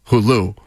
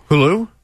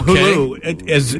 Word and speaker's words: Hulu: All I'm getting is tweets about saying, why is Fred pronouncing Hulu, Hulu? – Hulu? – Yes. – Like tattoo? – Tattoo? – Yeah, I do that completely to annoy people.